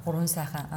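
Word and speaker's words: Gurvan Saikhan: And so one means of doing that would be to include the Great Gobi Strictly Protected Area with the, and I'm probably going to pronounce this wrongly, Gurvan Saihan National Park? – Gurvan Saihan, uh-huh.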